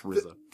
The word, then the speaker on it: RZA: And it's pronounced RZA.